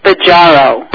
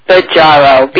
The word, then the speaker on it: Bajaro: Actually, it's pronounced Baggiaro. – Baggiaro. – Baggiaro. B-A-J-A-R-O.